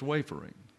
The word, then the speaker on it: wayfaring: Well, all of the dictionaries put the accent on the first syllable. But you have a poetic privilege to change accents when you need to in a song. But when it comes to this particular word, it's wafering.